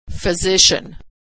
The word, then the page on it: physician: female